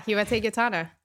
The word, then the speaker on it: Hiwote Getaneh: Hiwote Gitana.